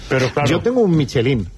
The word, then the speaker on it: Michelin: A ver, aquí en la península ibérica hacemos Michelin. – Sí, bueno, claro, pero la realidad, tú eres un chef internacional. – Ya, pero... – ¿Tú cómo lo pronuncias? – Me cuesta ser tan tontorraco, ¿no? Dice Michelin. – ¿Michelin? – Yo digo Michelin.